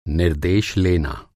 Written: निर्देश

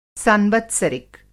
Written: सांवत्सरिक

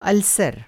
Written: अल्सर

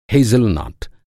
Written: हैज़लनट

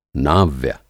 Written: नाव्य

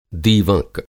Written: दीवँक

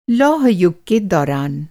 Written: लौह-युग

